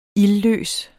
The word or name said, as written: ildløs